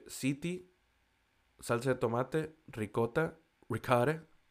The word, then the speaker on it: ziti: El siguiente que tenemos, el siguiente tipo que tenemos se llama Baked City, que no es una ciudad, se escribe Z-I-T-I.